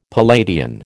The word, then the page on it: Palladian: American English